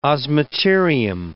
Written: osmeterium

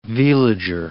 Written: veliger

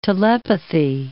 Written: telepathy

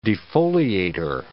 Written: defoliator

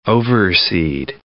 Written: overseed

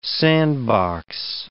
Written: sandbox

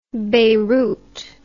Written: Bayrut

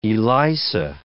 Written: ELISA

